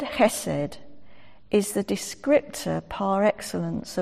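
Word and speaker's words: chesed (חסד): It's sometimes spelt with an H and it's sometimes with a CH because it's pronounced chesed. It's like Johann Sebastian Bach. It's that sort of ch.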